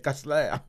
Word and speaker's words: Cussler: Que c'était Costler. Mais quand je fouillais, je disais pas Costler, c'est Costler. – C'est Costler. Alors, ça s'écrit donc comment? – C-U-S-S-L-E-R.